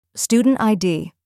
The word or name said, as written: student ID